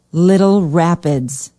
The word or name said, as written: Little Rapids